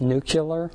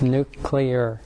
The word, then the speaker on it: nuclear: It just scares the crap out of me when we have a president that pronounces it nuclear. Just if you get anything out of this class, it's nuclear.